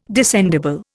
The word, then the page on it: descendible: Indian